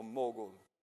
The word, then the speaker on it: mogol: creo que es mogol o mogol. No es igual que mongo, es mogol.